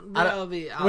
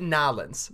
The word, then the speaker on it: New Orleans: Nolens. – Would you ever pronounce it Nolens? – No. – Okay, so then you're not a real New Orleans guy.